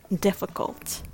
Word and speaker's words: difficult: The word difficult is pronounced as difficult uh, with three syllables. Let's break it down a little. The first syllable, diff, sounds like d if with a short i sound, i, like the word did, did. The second syllable, f, with f and a schwa sound, f, f. Finally, the last syllable, cult, just like C U L T, cult. Put it all together and you've got difficult. Difficult.